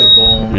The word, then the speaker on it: Gabon: But they pronounce it Gabon. – Yeah. – Well, that's the name of it in French.